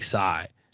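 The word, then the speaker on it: Tsai: Yeah, I thought it's like sigh. Sigh. Yeah. It's like the T's is silent.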